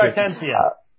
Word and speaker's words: Stuttgartensia: Stuttgartensia. – Yeah, that's the one. – he's asking for a comparison between the 1525 Blomberg, which was used in the King James, and the current Biblia Hebraica Stuttgartensia.